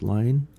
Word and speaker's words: Lean: And that provides a contrast with my other grandfather, Frederick William Lane, and that's spelt L-E-A-N and pronounced Lane.